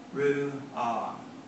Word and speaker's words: ruach: It's spelled R-U-A-C-H and it's pronounced ruah. It's the very sound of breath.